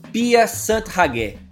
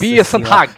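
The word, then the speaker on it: Pia Sundhage: Vou até repetir, ó. Pia Sant Hage. Nossa Pia Sant Hage, ó. – Ó, a é verdade, agora eu falei errado. Pia Sant Hage.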